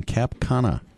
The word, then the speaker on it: Cap Cana: Considering a couple's trip to the Hyatt in Cap Cana. Did I say that right? – You Cap did. Cana? Right, yes.